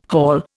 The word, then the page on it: haul: Indian